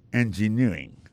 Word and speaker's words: engineering: Engineering, ça, l'accent tombé le même sur avant dernier syllabe là.